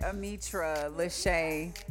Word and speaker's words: Ametra Lashay: And y'all can follow my sister, who was too damn tipsy to be focused on this shit, at Ametra Lashay. It's spelled the way it sounds, y'all.